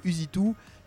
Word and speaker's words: Usitou: Donc Usitou, c'est la contraction de Use It To, utilise-le aussi. Du coup, ça s'écrit US I-T-O.